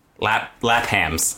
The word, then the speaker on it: Lapham's: That's not how you say it, bro. – Lapham's. – There you go.